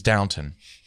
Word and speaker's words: Downton: I don't think it's spelled downtown. I think it's Downton.